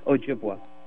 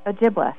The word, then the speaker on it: Ojibwa: And by the way, my tribe is pronounced Ojibwa. – Ojibwa.